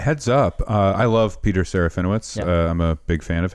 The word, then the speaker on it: Serafinowicz: I believe we all say Peter Serafinowicz, and I think it's Witch. – Witch.